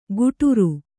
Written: ಘುಟುರು

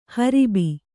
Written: ಹರುಬು

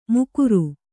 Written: ಮುಕುರು

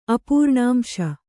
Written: ಅಪೂರ್ಣಾಂಶ

